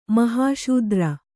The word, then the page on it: ಮಹಾಶೂದ್ರ: Kannada